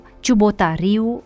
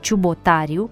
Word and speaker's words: Ciubotariu: ciubotariu în loc de ciubotariu, ferariu în loc de ferariu.